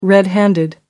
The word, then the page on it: red-handed: American English